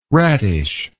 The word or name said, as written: rattish